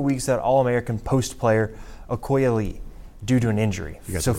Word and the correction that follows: Ayoka Lee: I should have given you a pronunciation guide on that. Ayoka Lee.